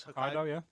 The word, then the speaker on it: Hokkaido: I believe it's Hokkaido.